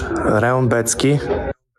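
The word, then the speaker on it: Rębecki: Rimbietski or something like that. – All right, let's hear from Mateusz. – I'm Mateusz Rembetski.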